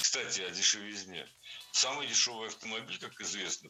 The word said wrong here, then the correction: дешевизне: Кстати, о дешевизне прозвучало не кстати, ведь в слове «дешевизна» ударение всегда падает на третий слог, поэтому должно быть «кстати о дешевизне».